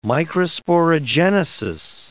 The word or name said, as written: microsporogenesis